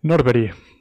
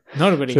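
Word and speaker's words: Norberg: Norberi, que es sueco. Norberi. Se pronuncia.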